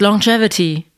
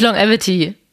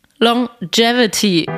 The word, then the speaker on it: Longevity: Es heißt Longevity, nicht Longevity. Longevity.